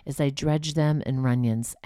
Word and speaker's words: Runyons: Runyons, spelled like onion, but with R-U.